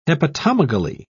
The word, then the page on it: hepatomegaly: British English